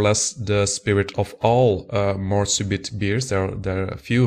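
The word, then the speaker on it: Mort Subite: In French, it would be Morsubeet, une Creek Lambic.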